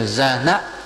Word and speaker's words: Jhana: Chứ trong cái tiếng Bali mà cái nào mà có dấu hiền á, thì đọc là A, mà không có dấu thì đọc thành ngắn, thành dấu sắc. Ví dụ đây đọc là Jhana.